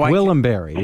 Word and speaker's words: Gwillimbury: Gwillimbury, yeah.